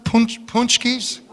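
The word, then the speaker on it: pączki: How do you pronounce it? Punchkis? Yeah, that. Gets a dozen of them.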